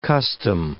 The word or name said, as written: custom